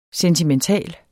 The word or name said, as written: sentimental